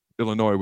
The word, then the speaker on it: Illinois: All right, Nebraska, Illinois, where the S is silent.